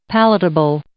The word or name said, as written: palatable